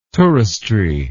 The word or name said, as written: touristry